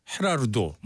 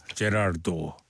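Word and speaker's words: Gerardo: 자, 9위는 에콰도르 출신이라 이거 스페인어로 발음하면 헤라르도 뭐 이렇게 발음되죠. 근데 뭐 미국에서는 다 DJ들이 제라르도라고 발음했습니다. 제라르도.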